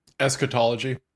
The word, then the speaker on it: eschatology: and eschatology. Is that how I pronounce that? Eschatology? – Eschatology. – That's it, yeah.